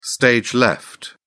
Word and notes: stage left: British English

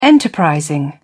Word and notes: enterprising: British English

